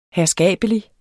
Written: herskabelig

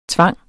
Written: tvang

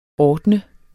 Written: ordne

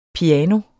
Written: piano

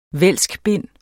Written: vælskbind

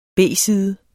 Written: B-side

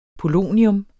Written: POLONIUM

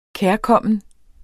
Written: kærkommen

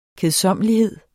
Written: kedsommelighed

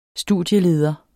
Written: studieleder

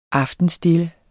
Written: aftenstille

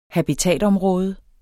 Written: habitatområde

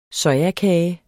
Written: sojakage

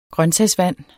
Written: grøntsagsvand

